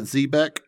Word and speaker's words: Xebec: Would one pronounce that Zebec? Is that how that works? – Sure.